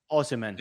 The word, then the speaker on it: Osimhen: And is it Ousmane? – Osimhen. – Yeah, Osimhen. – Osimhen. I forgot the men at the end.